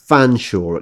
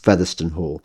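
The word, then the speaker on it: Featherstonehaugh: And it's particularly the case among upper class names like Cholmondeley, which is pronounced Chumley, and Featherston Hall, which is often pronounced Fanshawe, except when it's not, except when the families decided that they want it to be pronounced Featherston Hall.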